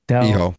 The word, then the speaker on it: Hijo: Felino Jr. El Hijo, how do you say it? El Hijo? – Hijo.